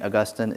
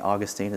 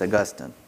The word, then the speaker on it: Augustine: In church history pronunciation lesson, St. Augustine is in heaven and St. Augustine is in Florida. Okay? It's Augustine.